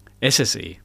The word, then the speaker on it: SSE: Du hast mir vorhin noch gesagt, wie genau wird das ausgesprochen? – SSE. – SSE, das einfach ganz so normal.